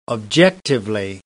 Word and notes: objectively: British English

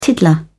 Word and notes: tiddler: British English

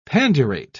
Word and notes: pandurate: British English

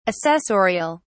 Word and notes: assessorial: British English

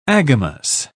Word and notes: agamous: British English

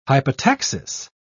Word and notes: hypotaxis: British English

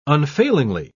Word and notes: unfailingly: British English

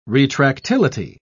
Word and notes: retractility: British English

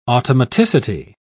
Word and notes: automaticity: British English

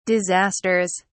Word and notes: disasters: British English

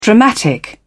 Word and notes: dramatic: British English

